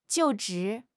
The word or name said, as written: 就职